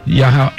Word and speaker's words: Yahel: Yahel.